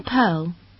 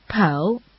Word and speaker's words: Pearl: It's Wang Pearl. – Excuse me? Is Pearl your last name? – No, it's my first name. My family name is Wang. – Can you spell that? – Sure. Pearl, P-E-A-R-L. Wang is spelled W-A-N-G.